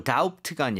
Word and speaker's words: doubt: D-O-U-B-T입니다. B가 있지만 B는 묵음이에요. – Okay, so doubt가 아니라 doubt.